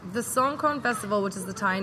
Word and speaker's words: Songkran: Am I saying that right? – Songkran. – Yes.